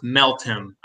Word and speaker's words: Meltem: and I believe her last name is pronounced Meltem. I know it, I always want to say Metalman or something, but I've heard it's Meltem.